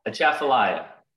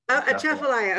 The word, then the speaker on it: Atchafalaya: Atchafalaya. – Atchafalaya, okay, because I added a few words in there.